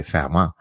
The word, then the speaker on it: Fermat: So for example, Fermat, the mathematician, in French you say Fermat, that's how you pronounce his name, F-E-R-M-A-T, was a number theorist who was doing research that collected dust for hundreds of years.